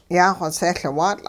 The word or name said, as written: yaaxosehLwaatL'